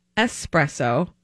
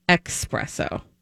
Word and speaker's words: espresso: Number one, the word is pronounced espresso, not expresso.